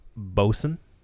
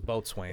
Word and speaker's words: Boatswain: So all of this brand, I know it looks like Boatswain, but it's actually pronounced Bosun. – See, there you go again. – Yeah. – Really? – Sorry. – I thought it was Boatswain.